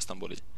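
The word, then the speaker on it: Stambouli: uttalar jag hans namn på två olika sätt men det borde vara Stamboli.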